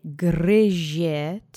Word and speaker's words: gryzie: Let's say gryzie together three times. And do try to roll that R. Accent is on the first syllable.